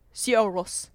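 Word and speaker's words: Sigur Rós: Sierros. – Yeah. – Sierros. – I only know that because one of my best friends who got me into Radiohead was also a big Sierros fan.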